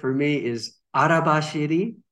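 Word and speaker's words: arabashiri (荒走り): Now, sometimes it's pronounced because we spell it in Roman letters on the bottle. So sometimes people say arabashiri. – Yeah. – And so on. So don't worry too much about the pronunciation. Here in Japan, we say arabashiri.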